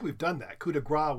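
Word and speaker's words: coup de grâce: But a lot of people, we found this a lot of people insist on not pronouncing those final French syllables, thinking incorrectly. – Well, it's like coup de grace, instead of coup de grace.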